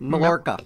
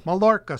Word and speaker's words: Mallorca: Mallorca. – Mallorca, Spain.